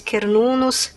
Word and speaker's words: Cernunnos: O deus mais antigo celta é o Cernunnos, que é ali, ali mais do país de Gales, junto com Ceridwen também. Escreve Cernunnos e Ceridwen, tá? Lê-se Cernunnos, Ceridwen.